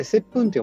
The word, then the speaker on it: 接吻: 接吻あれ、接吻って読むよね。キスじゃないよね。